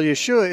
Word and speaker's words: Yeshua: Well, Yeshua is the Hebrew pronunciation or the Aramaic pronunciation of the name of Jesus.